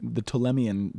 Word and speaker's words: Ptolemy: And what was happening at the time, this is during a period in the Greek Empire called the Ptolemyan dynasty, silent P on the front.